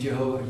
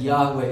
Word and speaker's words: Yahweh: And sometimes people say Jehovah, and I won't die on that hill arguing which it is Jehovah or Yahweh, but it's more likely that Yahweh is the right pronunciation of the word.